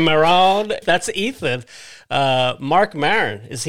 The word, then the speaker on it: Maron: Mark Maron. I think you said Marone or something like that.